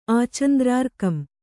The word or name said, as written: ಆಚಂದ್ರಾರ್ಕಂ